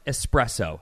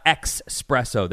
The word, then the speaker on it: espresso: It's pronounced espresso, not expresso. There's no X. It's not expresso.